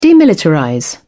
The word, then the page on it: demilitarize: British English